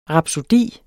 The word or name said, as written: rapsodi